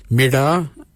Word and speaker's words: mirda: Mirda is spelled M-I-R-D-A.